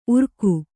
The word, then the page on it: ಉರ್ಗು: urgu